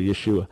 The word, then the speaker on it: Yeshua: And in point of fact, when Gentiles try to live like Jews and think that that's going to make Yeshua better known, that's the proper pronunciation, by the way, Yeshua.